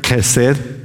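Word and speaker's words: chesed (חסד): Y al responder a esta pregunta, el profeta Miqueas utiliza una palabra hebrea que se pronuncia chesed. Se escribe C-H-E-S-E-D, pero la C-H en hebreo se pronuncia como la letra K. Y usted aprende a pronunciar hebreo con la letra K hasta que algo de adentro de la garganta le sale por la boca y le cae al que tiene enfrente. Es chesed. No existe una palabra que pueda traducirse a nuestro idioma español que pueda exactamente reunir el significado completo de la palabra chesed.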